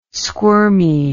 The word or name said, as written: squirmy